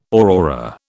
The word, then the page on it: aurora: British English